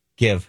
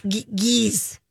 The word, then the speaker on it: GXVE: how would you Hollywood speak how to say this name? It's capital letters, GXVE. – I'd pronounce it... – Give. – Geese. – Give. – Give. – Yeah. – You got it. – I think it looks... This kind of language. – I don't... – That is how you pronounce her beauty line. It's called Give.